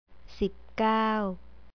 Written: sipkao

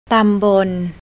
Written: tambon

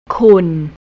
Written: kun

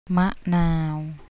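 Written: มะนาว

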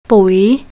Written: pui

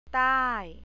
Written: tai

